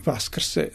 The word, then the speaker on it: Vaskrse: Pa trebalo bi Vaskrse, zato što znači mi smo dobili, to je rusko-slovenski uticaj, se pisalo se Voskrse, ali se kod Rusa uvijek izgovaralo kao Vaskrse, jer kod u ruskom jeziku nenaglašeno O se izgovara kao kratko A, prema tome i oni kada pišu Voskrse, oni izgovaraju kao Vaskrse.